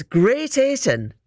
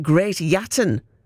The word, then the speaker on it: Great Ayton: Is it Great Ayton or is it Great Yatton? Is it? I've never heard it pronounced Great Yatton. Surely that's not right. Or is that from a bygone time? I don't know. I've never heard Great Yatton for Great Ayton ever.